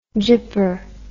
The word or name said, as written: gypper